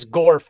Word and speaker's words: Gorf: OK, my nickname is Gorf, which is the first four letters of my last name, Jordan B. Gorfinkel Gorf. And if you spell it backwards, of course, you get frog.